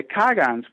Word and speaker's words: Kagan: That's another way to pronounce it. I just want to put that out there in case people are used to hearing it pronounced Kagan and they don't recognize it with your pronunciation.